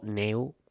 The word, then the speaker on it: Neu: En el último de sus temas, además, homenajean a sus compatriotas Neu o Neu, que es como se pronunciaría literalmente el término alemán.